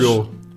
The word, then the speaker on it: choro: Lloro. – No, lloro, si yo lo digo, a veces lo digo mal. Ah. Lloro es con sh.